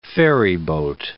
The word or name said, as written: ferryboat